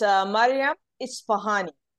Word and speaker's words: Mariam Ispahani: It's Mariam Ispahani.